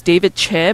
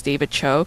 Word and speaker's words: Choe: It's actually pronounced David Che, but everybody mispronounces it as David Cho because it's spelled No. – Choe.